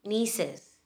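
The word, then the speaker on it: neesings: What does it sound like? – Nieces. – Nieces, okay, good enough, which obviously would be like you're an auntie, and you have, you know, these, your sister's daughters, right?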